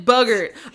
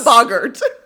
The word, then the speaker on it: boggart: He pronounces it boogert. – He's wrong. – I rewound it 5 times. – It's a boggart. – And he said bugger.